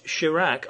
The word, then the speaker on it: Chirac: The highlight of this year's interview came when the president, Jacques Chirac, notice how that rhymes with Iraq? Ever notice that? Yes, Jacques Chirac unburdened himself of some opinions about the superiority of his nation to the uncouth and perfidious Anglo-Saxons.